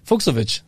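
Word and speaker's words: Fucsovics: Oh, wie wird er ausgesprochen? Du musst es mir aber auch dann richtig sagen. – Ja, ich sag's dir. – Den kann ich nicht aussprechen. Fuchsovic, so. Fuchsovic, sehr gut.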